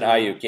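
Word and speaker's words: Aiyuk: I like Brandon Ayuk. Is that how I pronounce it? – Brandon Ayuk. Yeah, interesting fact.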